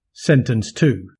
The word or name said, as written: sentence to